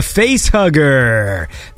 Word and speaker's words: Face X Hugger: This is a track from Face Hugger. That's Face X Hugger, but the X is silent.